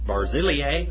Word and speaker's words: Barzillai: But show kindness to the sons of Barzillai.